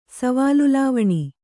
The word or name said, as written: ಸವಾಲುಲಾವಣಿ